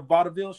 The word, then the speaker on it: vaudeville: Have you ever seen a vaudeville show? – No, not live.